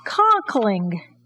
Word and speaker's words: Conkling: Stop number four on our tour is Conklin. – I've always heard it pronounced Conkling. – The name was changed from Conklin to Conkling on April 19th, 1881.